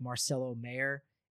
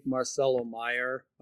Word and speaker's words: Marcelo Mayer: I think Marcelo Mayer, I believe, is the correct pronunciation.